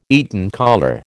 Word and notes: Eton collar: American English

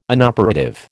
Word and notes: inoperative: American English

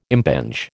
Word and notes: impinge: American English

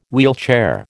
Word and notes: wheelchair: American English

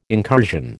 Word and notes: incursion: American English

